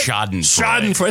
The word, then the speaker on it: Schadenfreude: Shows coming up with Schadenfreude. Did I say it right? – Schadenfreude. – It's a tough word to say. – Schadenfreude. It's German. Mein Gewalt.